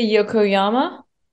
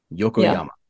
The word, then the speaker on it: Yokoyama: Oh, um, Sofya Yokoyama. I don't even really. Yokoyama.